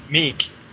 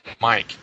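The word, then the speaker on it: Meik: I think it was Michael Cunningham, Techie Michael, was calling him Meek. – Huh. – And because it's M-E-I-K. – Uh-huh. – And so Acidburn corrected us on that and said it's actually pronounced as Mike.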